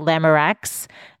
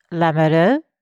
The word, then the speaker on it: Lamoureux: Most people call me Michelle Lamoureux when they see my name. It's actually in French pronounced Lamoureux, which means the one who is in love.